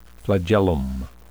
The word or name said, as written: flagellum